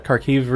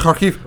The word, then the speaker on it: Kharkiv: So they've actually, in the Kharkiv region... – Look at you pronouncing it all right. – Yeah. Gesundheit. – Except for region, I've slurred on that. – Kharkiv.